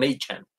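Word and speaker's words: Achane: es que todo el mundo lo ha estado pronunciando mal, pronunciando mal. Este, yo soy Devon Achan.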